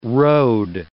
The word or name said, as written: rode